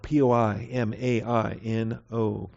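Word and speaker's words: poimaino (ποιμαίνω): Versus poimaino, P O I M A I N O, poimaino.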